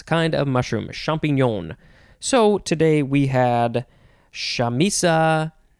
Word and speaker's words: champignon: Next and last word for this episode is champignon. Champignon, champignon, champignon, champignon. You can say it that way too, champignon. You get it. It is spelled C-H-A-M-P-I-G-N-O-N, champignon.